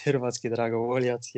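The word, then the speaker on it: Hrvatski Dragovoljac: You went to, if I can say it right, is it Hrvatski Dragovoljak? You moved to that club. How was the change going to Croatia? – Well, first of all, it's Hrvatski Dragovoljak.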